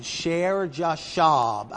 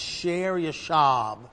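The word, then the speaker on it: Shear-jashub: Then the Lord said to Isaiah, go out now and meet Ahaz, you and your son, Sher Jashab. Actually, it should be pronounced Sher Yashab.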